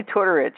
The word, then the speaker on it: Tortorich: Oh, Vinny Tortorich? – Vinny Tortorich, yes.